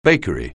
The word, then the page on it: bakery: anglais